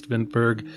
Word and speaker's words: Vinterberg: This film from director Thomas Vinterberg.